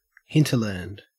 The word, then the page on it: hinterland: Australian English